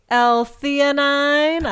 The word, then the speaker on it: L-theanine: I would like to thank the L-theanine. I don't know if I'm saying that right, but I guarantee you're saying that correctly.